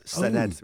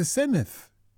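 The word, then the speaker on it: Senedd: But unfortunately, a lot of people on the news can't seem to gist the fact that it's the Sened with a th, not Sened with a hard D. – It's not a Sened, it's the Senith. – Yeah, exactly. – I thought you said it's pronounced just as it's written. – But it's the double D, yeah, so that's that creates a th sound.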